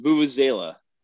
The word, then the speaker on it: vuvuzela: Wait, the vuvuzela is that long horn? – It's yep. Pronounced vuvuzela.